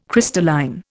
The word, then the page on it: crystalline: Indian